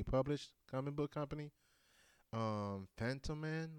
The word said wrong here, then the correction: Fantoma: Oh, Fantoma, yeah.